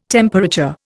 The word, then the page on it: temperature: Indian